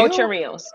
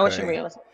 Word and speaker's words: Ocho Rios: Am I saying it right? Ocho. – Rio? Ocho Rios. – Mm-hmm. Okay. Ocho Rios.